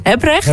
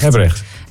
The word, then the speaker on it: Hebrecht: Die zegt de klemtoon ligt op de eerste lettergreep, dus Hebrecht. – Hebrecht.